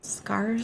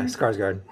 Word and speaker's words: Skarsgård: I think I said his name right. – Skarsgård? – Oh, yeah, Skarsgård.